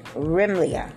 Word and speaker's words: Remaliah: And Hoshea, the son of Elah, made a conspiracy against Pekah, the son of Remaliah. And that is a silent, a short E, Remaliah, and smoked him and slew him and reigned in his stead in the 20th year of Jotham, the son of Uzziah.